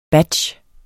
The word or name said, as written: batch